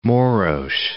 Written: Maros

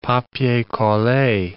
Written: papier colle

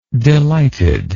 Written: delightedness

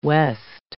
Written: west

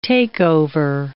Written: takeover

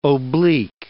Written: oblique

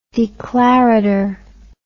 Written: declarator

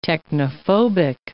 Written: technophobic

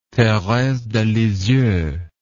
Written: therese de lisieux

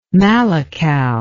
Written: malakal

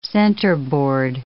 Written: centerboard

